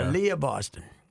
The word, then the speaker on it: Aaliyah Boston: Aaliyah Boston.